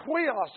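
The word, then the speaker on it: huios (υἱός): That's the word huios. It's spelled U-I-O-S with an H sound in front.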